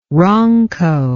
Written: ronco

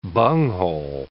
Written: bunghole

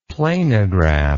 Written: planigraph

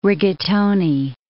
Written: rigatoni

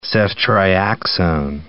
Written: ceftriaxone

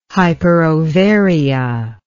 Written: hyperovaria

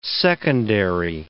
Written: secondary

